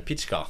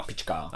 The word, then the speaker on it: Pitschka: Det är så man ska uttala det, Pitschka. Pitschka. Ja. Ehm, har han ju själv varit här och berättat.